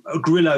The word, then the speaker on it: Grillo: So Grillo is how I pronounce it growing up, but Grillo he'd call me.